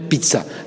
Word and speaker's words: pizza: La pizza, c'est italien. Même dans la si on le prononce bien, on dit les Français en français, on dit pizza. C'est la pizza.